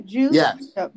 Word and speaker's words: Juszczyk: He gives me Kyle Juszczyk. I don't know how you pronounce his name. – Only the 40. Juszczyk.